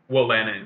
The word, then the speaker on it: Wolanin: Is that how you pronounce it? Wolanin? – Wolanin, yeah.